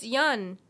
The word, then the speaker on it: Jung: Oh, it's Yun. – Yun. – Yun. – So the G is silent.